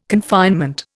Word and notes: confinement: Indian

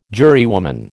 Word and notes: jurywoman: American English

